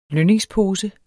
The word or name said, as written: lønningspose